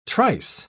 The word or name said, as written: trice